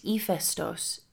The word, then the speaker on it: Hephaestus: Stories about Hephaestus, pronounced Hephaestos in Greek, emphasize his ugliness and the fact that he walked with a limp.